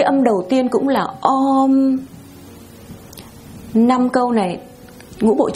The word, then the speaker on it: Om: Họ phát âm chữ Om, họ kéo dài ra và Om, giống như là những cái tiếng gọi của chư thiên trong trời đất đó.